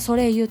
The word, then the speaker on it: Soleil: そしてソレイユなんですけれども、 S O L E I L で、これね、日本語だとソレイユって言うんですけど、英語だとソレイで、 E に至ってはあんまり発音はっきりしません。